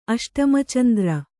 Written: ಅಷ್ಟಮಚಂದ್ರ

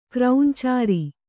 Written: ಕ್ರೌಂಚಾರಿ

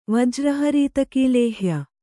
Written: ವಜ್ರಹರೀತಕೀಲೇಹ್ಯ

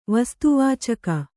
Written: ವಸ್ತುವಾಚಕ

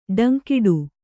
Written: ಡಂಕಿಡು

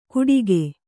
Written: ಗುಟಿಕೆ

